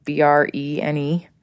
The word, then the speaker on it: Brené: Her name is Brene.